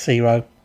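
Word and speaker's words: C-RHO: So it's C-R-H-O, pronounced Ciro.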